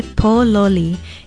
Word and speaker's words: pololi: In Hawaiian, we say pololi. It is spelled P-O-L-O-L-I, and the first syllable is stressed, pololi.